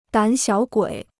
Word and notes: 胆小鬼: dǎn xiǎo guǐ

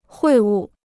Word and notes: 会晤: huì wù

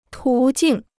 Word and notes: 途径: tú jìng

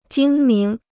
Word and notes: 精明: jīng míng

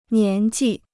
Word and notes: 年纪: nián jì

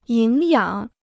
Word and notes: 营养: yíng yǎng

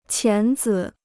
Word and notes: 钳子: qián zi

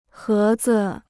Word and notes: 盒子: hé zi

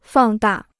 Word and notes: 放大: fàng dà

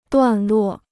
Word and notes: 段落: duàn luò

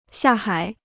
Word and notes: 下海: xià hǎi

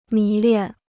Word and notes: 迷恋: mí liàn

